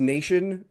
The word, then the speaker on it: n8n: I'm not sure how they pronounce it, but it's N, the letter N, the number 8, the letter N, N8N. – Okay. – I've also heard it pronounced Nation as well, but I think it's just N8N.